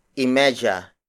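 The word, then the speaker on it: y medya: If it is half hour like 2:30, we add y media. Y media. Y media. Y media is spelled as Y space M-E-D-Y-A.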